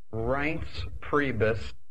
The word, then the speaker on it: Reince Priebus: Reince Priebus. – Reince Priebus.